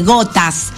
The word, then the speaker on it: Ghotazz: Gotas, que se llama así, Gotas. ¿Cómo se escribe el grupo? – Se escribe G-H-O-T-A-Z-Z. – Claro, como repito, si G-H-O-T-A-Z-Z.